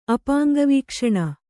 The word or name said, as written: ಅಪಾಂಗವೀಕ್ಷಣ